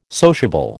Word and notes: sociable: American English

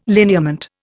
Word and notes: lineament: Indian

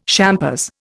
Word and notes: champers: Indian

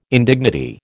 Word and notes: indignity: American English

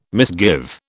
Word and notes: misgive: British English

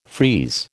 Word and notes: freeze: American English

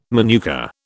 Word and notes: manuka: British English

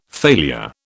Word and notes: failure: British English

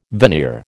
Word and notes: veneer: American English

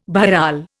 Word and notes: bharal: Indian

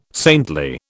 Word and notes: saintly: British English

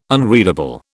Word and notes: unreadable: British English

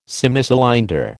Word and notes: Semicylinder: American English

